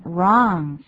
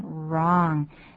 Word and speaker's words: wrong: 请注意发音，wrong 是这样的发音，wrong。嘴型是由小到大，哇哇这样的发音，wrong。